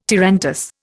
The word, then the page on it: tarantass: Indian